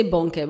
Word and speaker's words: Bonnke: we say Bonnke, right? Bonnke, right? Yeah. You can't pronounce the K. – Yeah. – Bonnke.